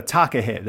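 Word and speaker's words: takahē: So, you know, anyone out there listening on the gargle, you know, it's go to find the takahe. There's an accent on the E.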